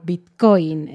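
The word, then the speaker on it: bitcóin: Se escribe igual que en inglés, pero con una tilde o un acento en la O, Bitcoin.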